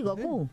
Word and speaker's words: Goku: Sí, Goku.